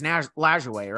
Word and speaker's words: Lajoie: Is it Lajoie? I think it's Lajoie, right? – Yes.